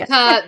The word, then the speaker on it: Utica: Utica, the town is spelled with a Y. – It's a U. U-T-I-C-A. Utica.